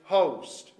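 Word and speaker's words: Hoste: His name was Dixon Edward, I think you pronounce it, Host. It's Host with an E on the end of it.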